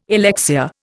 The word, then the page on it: alexia: Indian